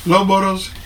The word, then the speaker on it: Loughborough: ¿Loughborough? – Loughborough.